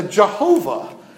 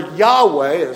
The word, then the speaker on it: Yahweh: But capital L, capital O, capital R, capital D is a Jehovah, is the old way of pronouncing it, or Yahweh, as more modern scholars pronounce it, and there are reasons for that.